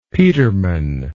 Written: peterman

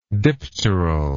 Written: dipteral

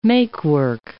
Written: make-work

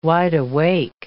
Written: wideawake

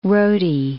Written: roadie

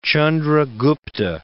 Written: Chandra Gupta II

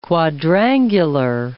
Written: quadrangular

